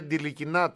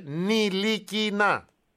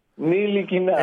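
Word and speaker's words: Ntilikina: Δεν λέγεται Ντιλικινά, το ταφ δεν διαβάζεται. Λέγεται Νιλικινά. – Νιλικινά. – Έλα, προχώρα, αστειεύομαι. Προχώρα, αλλά αυτό είναι το σωστό όνομα. Νιλικινά.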